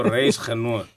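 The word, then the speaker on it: reisgenoot: How do you say it in Afrikaans? Your dagslegger. – There you go. – There you go. Reis genoot. – Yeah, that's it.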